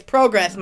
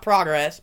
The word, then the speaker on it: PROGRESS: It's progress, Michael, not progress, but...